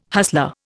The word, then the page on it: hustler: Indian